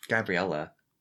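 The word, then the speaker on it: Gabriele: Gabrielle Amort. – Gabriella. – That's how he says it. Gabriella? – It's spelled Gabrielle, but he says Gabriella.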